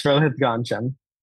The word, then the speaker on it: Rohith Ganjam: Yes, Rohith Ganjam.